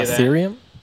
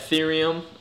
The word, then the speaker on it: Ethereum: I'm gonna butcher this name. Erythium, however you say Ethereum? – That. Ethereum. I can get behind that.